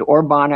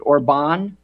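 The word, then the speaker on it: Orbán: Is it Orban, accent on the last syllable? – No, how you say it is Orban. You have to roll the R, Orban.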